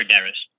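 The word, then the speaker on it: Derysh: How do you say it, by the way? I just want to make sure. – It's Igor Daryush.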